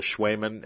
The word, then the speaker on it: Cwejman: a module called the Schwemann S1. It's spelled C-W-E-J-M-A-N.